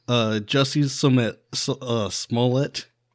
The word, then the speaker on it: Jussie Smollett: Jussie Smollett, that's how you pronounce his name, right? – Yes.